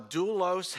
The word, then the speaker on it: doulos: D-O-U-L-O-S, doulos. I want you to remember that word doulos. I'll preach it again sometime. A doulos is the lowest slave, the lowest servant in the household. A doulos has everybody above him or her.